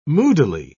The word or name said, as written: moodily